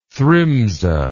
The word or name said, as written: thrymsa